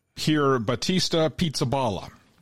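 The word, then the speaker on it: Pierbattista Pizzaballa: There is a fellow, his name is making the rounds, Cardinal Pier Battista Pizzaballa. And that's his real name. It is spelled just like it sounds.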